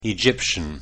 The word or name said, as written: egyptian